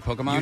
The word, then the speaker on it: Pokemon: Pokemon? – You did.